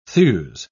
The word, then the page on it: thews: British English